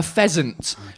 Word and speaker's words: pheasant: I'm like, yeah, you know, a peasant. The bird thing. And they're like, oh no, a pheasant. Okay. PH, you got to add the H. You got to pronounce the H.